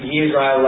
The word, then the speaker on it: Israel: And it's pronounced Israel like with a Y.